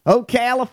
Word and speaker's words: Ocala: Ocala, Florida!